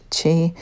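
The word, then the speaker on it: qi: it's Q-I, but it's pronounced chi,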